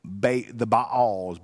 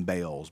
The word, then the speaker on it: Baal: were a group of gods called the Baals, B-A-apostrophe-A-L, or Baals. That's, we often call them Baals, but Baal is also the pronunciation of it.